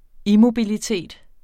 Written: immobilitet

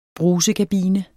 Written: brusekabine

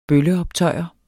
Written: bølleoptøjer

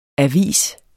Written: avis

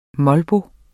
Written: molbo

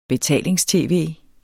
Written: betalings-tv

